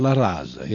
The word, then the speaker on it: La Raza: And we see Abe Foxman of the ADL hand in hand with the president of La Raza, or La Rosa, however you may pronounce that. – La Raza, yes.